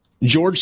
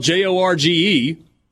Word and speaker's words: Jorge: Okay, so ESPN radio host, I think he's out of Chicago, George Sedano. It's spelled J O R G E and I always thought it was Jorge. And then I heard him pronounce it George.